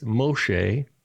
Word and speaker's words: Moshe: And it's just like Moses, right? It's not, it's Moshe, but the Greek didn't have a sh, so they use a sigma.